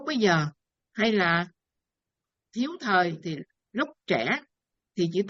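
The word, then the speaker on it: thời (時): 39, văn ngạn bác thiếu thời đều là chữ thời, hay là có khi người ta đọc là chữ thì nữa.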